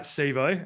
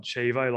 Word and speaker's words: SIBO: So with that, Mark, let's talk about SIBO, and I am pronouncing it correctly, not Chivo, like I have forced myself to not do, like we talked about earlier.